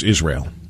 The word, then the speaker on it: Israel: First of all, the word is Israel. It's not a three-syllable word.